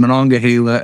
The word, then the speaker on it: Monongahela: Uh, so there's Monongahela and then there's Monongalia.